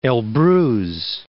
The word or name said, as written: Elbrus